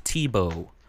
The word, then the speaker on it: Tebow: It's not Thibault. – Yes, even though that's how it's spelled, we had the wisdom to message her and ask her for pronunciations. And she told us it's actually pronounced Tebow.